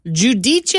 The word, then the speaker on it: giudice: So the word for judge is giudice. – Giudice. – It's spelled G-I-U. – Yeah. – D-I-C-E. – Giudice. – And it's pronounced giudice.